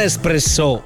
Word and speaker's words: espresso: Espresso. Vai, ripeti, ripeti. Come fa? No, non espresso. Espresso. È proprio l'accento che...